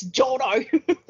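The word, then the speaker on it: Jourdan: And Louis Jordan, is that how you say it? I don't know. – Jordan, mate. – Jordan. They say Jordan. It's Jordo. – Louis Jordo. – Jordo. – It is full French name, Jordo.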